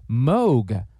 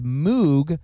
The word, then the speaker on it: Moog: Well, the surname of the late Robert Moog, who invented the legendary Moog synthesizer, is actually pronounced Moog, rhyming with rogue. But everyone has said Moog for so long that no one bothers ever to correct it anymore.